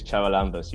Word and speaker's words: Charalambous: It's Charalambous.